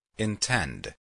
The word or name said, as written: intend